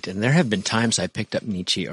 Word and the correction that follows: Nietzsche: or somebody like that, or to say it correctly, Nietzsche.